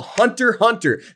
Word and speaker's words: Hunter x Hunter: It's called Hunter x Hunter. There's an X in the middle, but you don't pronounce the X unless you want everybody to know that you don't know what you're talking about.